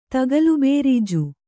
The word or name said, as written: ತಗಲುಬೇರೀಜು